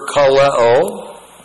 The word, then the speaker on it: kaleo: And that verb called is the Greek verb kaleo. K-A-L-E long O. Kaleo.